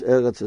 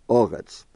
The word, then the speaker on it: Eretz: That sometimes Eretz is pronounced with the Segal Eretz, and sometimes where there's an Esnachta, Oretz.